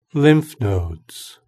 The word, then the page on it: lymph nodes: British English